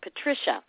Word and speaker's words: Patricia: Patricia, P-A-T-R-I-C-I-A, and my last name is spelled S, as in Sam, H-I-H.